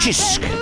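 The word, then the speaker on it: Cisk: And apparently the beer that Brendan is drinking in Malta is called, well, it's called Sis, but it's pronounced Chisk.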